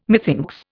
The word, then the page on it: methinks: Indian